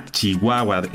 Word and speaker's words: Chihuahua: Pues así es, el día de hoy toca celebrar a Chihuahua, estado excepcional, el estado más grande de nuestro país.